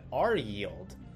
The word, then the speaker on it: RYLD: It's actually come to my attention that these Global X ETFs like QYLD and RYLD can actually be pronounced just like Q yield and R yield, which just blew my mind, and I'll probably be using them interchangeably throughout the video.